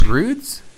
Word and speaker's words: Broods: What was there was a Broads video this week that was super cool. – Isn't it Broods? – I think so. – That's right, Broods. Laugh, L-A-F.